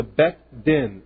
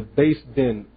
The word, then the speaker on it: Beth Din: In the Beth Din, in the Beis Din, as it some pronounce it,